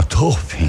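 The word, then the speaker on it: topping: A pronúncia é assim, ó, Toping.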